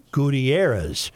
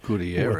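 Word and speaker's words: Gutiérrez: The Spanish last name Gutierrez is pronounced Gutierrez. – Gutierrez. – With the stress on the air.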